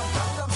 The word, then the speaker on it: Gangnam: Como é que fala Gangnam? – Gangnam. – Né? É, Gangnam. Tem até aquela música.